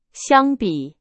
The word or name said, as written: 相比